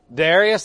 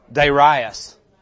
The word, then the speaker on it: Darius: Darius, that's the way I've always pronounced it until I was listening to my YouVersion Bible, and it was Darius.